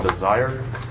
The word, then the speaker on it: DSIRE: Department of Energy called DESIRE. That's spelled D-S-I-R-E. It's not like passion.